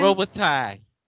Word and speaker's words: Robitaille: Robitaille.